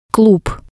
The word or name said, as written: klub